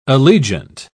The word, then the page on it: allegiant: British English